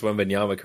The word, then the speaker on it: Wembanyama: I think what I'm most shocked by is that you pronounce Wembanyama correct.